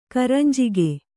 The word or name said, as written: ಕರಂಜಿಗೆ